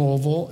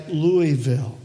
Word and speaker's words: Louisville: And by the way, I used to live in Louisville. It took me a while to figure this out, but you don't say Louisville, right? Right? It's Louisville, okay? L-O-A-V-U-L. That's how you say it.